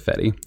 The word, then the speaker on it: Fede: I listened to an interview with him on Shockwaves recently, and they pronounce it Fede.